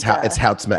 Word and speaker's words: Houtsma: It's Houtsma, actually.